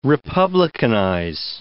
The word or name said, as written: republicanize